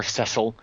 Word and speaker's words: Cecil: You're talking about lions and tigers, lions like Cecil the Lion or Cecil, if you want to go with sort of a British pronunciation, which I kind of like.